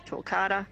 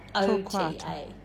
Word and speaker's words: Torquata: So if I go to Perth and go to a native nursery and ask for dwarf Torquata... – Yep. You will definitely find them. They're very popular. All the nurseries have them. – How do you spell it? – T-O-R-Q-U-A-T-A. – Torquata.